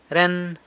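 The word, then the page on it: ren: San Lucas Quiaviní Zapotec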